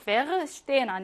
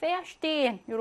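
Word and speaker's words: verstehen: 이런 전철에서는요, 페르슈텐 아니고요, 페어슈텐 이렇게 발음하면 됩니다.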